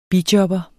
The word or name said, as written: bijobber